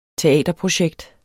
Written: teaterprojekt

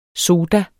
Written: soda

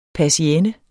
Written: persienne

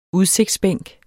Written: udsigtsbænk

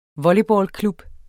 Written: volleyballklub

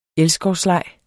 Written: elskovsleg

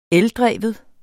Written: ELDREVNE

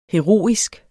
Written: heroisk

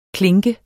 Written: klink

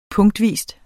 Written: punktvis

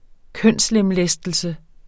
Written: kønslemlæstelse

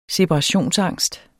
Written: separationsangst

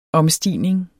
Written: omstigning

